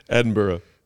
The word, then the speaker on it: Edinburgh: Edinburgh. Burrow. – Mm-hmm. – Shout out. Edinburgh? Is it Edinburgh? That's how it's spelled. – Edinburgh. – Yeah. Edinburgh. – You were close.